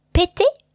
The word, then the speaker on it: péter: Now, the verb péter, which is spelled a little bit like the name Peter, but with E acute on the first, so the first E has an accent aigu on it.